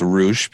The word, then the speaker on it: Rush: Spelled Rush, but pronounced Roosh, because Roosh, when you are of Mexican descent and you try to say the word Rush, it comes out Roosh.